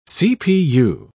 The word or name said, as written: cpu